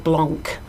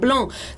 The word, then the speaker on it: Blanc: It's like the chef, you know, an English person would say Raymond Blanc, but you don't pronounce usually the final consonant, so it's Raymond Blanc.